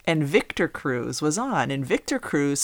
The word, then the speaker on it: Cruz: And I said, no. I said, that's pronounced Cruz. I said, like Terry Crews from America's Got Talent.